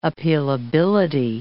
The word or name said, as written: appealability